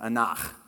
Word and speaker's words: anach: And it's this word, it's this Hebrew word called, this is how you pronounce it, anach. Anach. You have to kind of clear your throat at the end of it, all right?